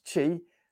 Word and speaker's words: qi: I'm not really that good with the terms of your chakras, but you know, your sacral chakra, you actually help allow life energy called chi, interestingly sort of spelled qi, but it's pronounced chi, that life energy to sort of go in.